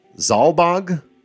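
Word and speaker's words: Zalbag: So Ramza's brothers, Zalbog and Dice Darg. That's how I'm pronouncing the names? – Me too.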